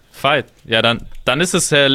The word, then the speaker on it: Veit: Valerie spricht man wie W, Valerie, und Veit spricht man wie F. Und trotzdem schreibt man es mit V.